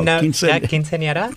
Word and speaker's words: quinceañera: Did you have a quinceañera? Am I saying it right? No. – Quinceañera? – Quinceañera. There we go.